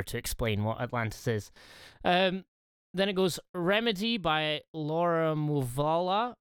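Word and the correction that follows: Mvula: I'm butchering the pronunciation, but she's like this kind of synth poppy. She plays the guitar. Awesome. – What's her name? – Laura Muvula. Muvula. – Muvula. – Yeah.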